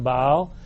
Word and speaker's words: Baal: The word Baal means, and that would be a more correct pronunciation, Baal means Lord, possessor, or husband.